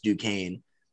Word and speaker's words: Duquesne: First of all, don't know how it's spelled like that and then pronounced Duquesne.